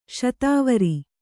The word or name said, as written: ಸತ್ವರ